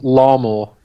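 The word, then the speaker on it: Larmour: Jordan Lamore. – It's pronounced as Larmor this Larmor. Weekend.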